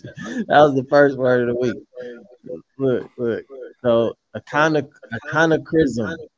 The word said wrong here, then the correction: anachronism: I said aconochrism. God damn it. I'm mixing two words. My bad, because I was going to pick another word. Anachronism. God damn. Anachronism. Okay. Anachronism. So basically, it's something that doesn't fit its time period.